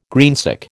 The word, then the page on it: greensick: American English